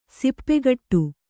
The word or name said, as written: ಸೊಪ್ಪುಗುಟ್ಟು